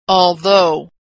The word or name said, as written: although